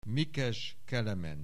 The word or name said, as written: Mikes Kelemen